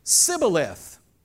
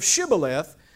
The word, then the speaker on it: Shibboleth: He would say Sibboleth instead of Shibboleth because people from Ephraim cannot pronounce the word correctly.